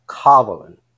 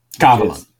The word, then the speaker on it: Kavalan: You did screw up the pronunciation. It's Kavalan. – Kavalan. And the emphasis is almost like there's an invisible H after the first A. – Ka, like K-A-H. – Exactly. It means God in the Tamil language. – So it's on, the emphasis is on what we call the anti-penultimate syllable, the third.